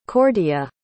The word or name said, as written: Cordia